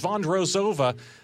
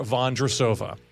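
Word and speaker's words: Vondroušová: Vondrosova.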